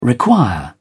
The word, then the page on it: require: British English